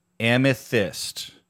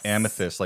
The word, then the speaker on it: Amethyst: Amethyst. – Yes. – Amethyst, like the stone.